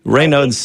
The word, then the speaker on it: Raynaud's: What can you tell me about Raynaud's, however it's pronounced, syndrome? – Raynaud's.